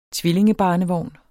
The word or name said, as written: tvillingebarnevogn